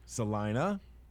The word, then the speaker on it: Celina: up on the plateau, the county seat of Clay County is pronounced Celina. Celina. Celina. not Salina or anything like that.